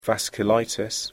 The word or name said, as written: vasculitis